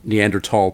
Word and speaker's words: Neanderthal: And there was a real movement at that time to pronounce the word the German way, which would be Neanderthal, it means Newman's Valley, after the place where the first specimen was found,